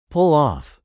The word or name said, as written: pull off